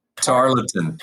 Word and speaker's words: Tarleton: Tarleton.